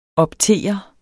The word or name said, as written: optere